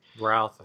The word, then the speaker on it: Routh: Brandon Routh or Ruth, I don't know how you say his name. – Routh, who, who plays the Atom on Legends, he was Superman in Superman Returns.